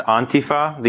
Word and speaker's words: Antifa: Yes, well, it's pronounced Antifa. The emphasis is on the first syllable, and it's pronounced more on than and, so Antifa is commonly mispronounced.